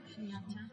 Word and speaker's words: shunyata: Shunyata. Shunyata. Jnanam. Shunyata. Jnanam. So this means understanding emptiness. There's a little dot under this H. – And a short U shunyata. Sorry. It's a long U. Yes, thank you.